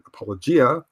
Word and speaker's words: Apologia: And of course, we know you from the popular Evolution 101 and Apologia podcast. Did I pronounce that correct? Apologia or Apologia? – No, actually, you pronounce it correctly. I pronounce it incorrectly. I pronounce it Apologia, which I know is incorrect, but I like it better.